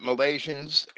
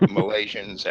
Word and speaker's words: Milesians: Yeah, I pronounce it Malaysians. It doesn't really matter. Not Malaysians in the South Pacific, but Malaysians, M-I-L-E-S-I-A-N-S. I mean, that's just how I've always pronounced it. For right or wrong, I don't care. I can spell it.